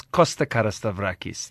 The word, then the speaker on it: Carastavrakis: It's Costa Carastavrakis.